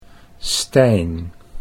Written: Stijn

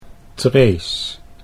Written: Trees

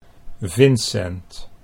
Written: Vincent

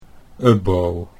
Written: Ubbo

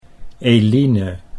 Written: Eline

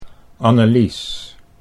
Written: Annelies

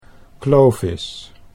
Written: Clovis

